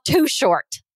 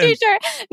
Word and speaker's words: Too Short: Okay, number two, and I know right now this is like, this is showing my age, okay, but I love Too Short. – represent. – I love t-shirt. No, say it like short.